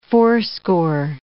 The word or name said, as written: fourscore